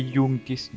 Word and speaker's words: Iunctis: Ouais, comment on prononce ce forum? Ouais. C'est Youngtis, normalement. – Moi, je dis Youngtis, mais.